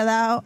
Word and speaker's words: Dao: Dao, se pronuncia Dao, eh, o el apellido es Dao, pero si usted busca United Airlines, eh, memes, ya lo van a ver.